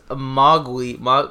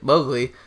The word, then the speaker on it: Mowgli: Andy Serkis's Mowgli? That's how you say it. Mowgli.